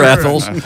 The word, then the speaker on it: Athol: A-T-H-O-L.